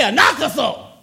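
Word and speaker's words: anakazo (ἀναγκάζω): Compel, say anakazo. Oh, y'all ain't saying it right. Say it right, anakazo.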